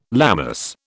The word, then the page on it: Lammas: British English